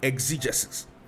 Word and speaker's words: exegesis: exegesis. Now, what do these terms mean? One is spelled E-I-S-E-G-E-S-I-S. That's eisegesis. The other is spelled E-X-E-G-E-S-I-S. And that is exegesis.